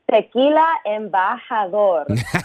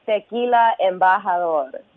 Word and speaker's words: Tequila Embajador: Tequila Embajador. – That's right. – Record it, replay it, re-air it, Tequila Embajador.